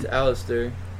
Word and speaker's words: Aleister: That's how you say his name. – It's Aleister.